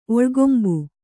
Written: ಒಳ್ಗೊಂಬು

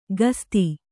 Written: ಕಸ್ತಿ

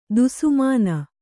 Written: ದುಸ್ಮಾನ

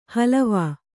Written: ಹಲವಾ

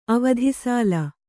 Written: ಅವಧಿಸಾಲ

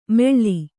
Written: ಮೆಳ್ಳಿ

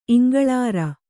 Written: ಇಂಗಳಾರ

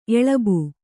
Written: ಎಳಬು

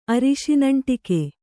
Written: ಅರಿಶಿನಂಟಿಕೆ

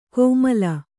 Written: ಕೋಮಲ

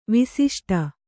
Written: ವಾಸಿಷ್ಠ

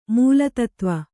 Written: ಮೂಲತತ್ವ

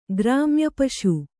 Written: ಗ್ರಾಮ್ಯಪಶು

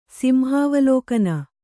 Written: ಸಿಂಹಾವಲೋಕನ

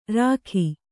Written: ರೇಗು

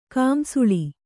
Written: ಕಂಸಾಳ

